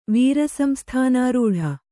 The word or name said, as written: ವೀರಸಂಸ್ಥಾನಾರೂಢ